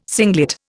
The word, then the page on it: singlet: Indian